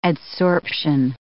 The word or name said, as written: adsorption